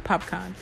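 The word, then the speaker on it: Popcaan: Did I say his name right? Yeah, Popcorn.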